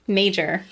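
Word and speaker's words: Mauger: And for clarity for people who are interested in researching later, that is spelled M-A-U-G-E-R. I'm glad someone created the pronunciation guide for that. – Because, like, yeah. Ew. Wait, so it's... – Major. – It's major. So, yeah, I would not have gotten major out of looking at this.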